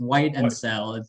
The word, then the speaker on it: Whitesell: It's literally just white and sell.